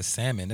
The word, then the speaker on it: salmon: Then they got the salmon. Then they got the salmon. – You know, it really hits my ear like a fireball when people come in and pronounce the L.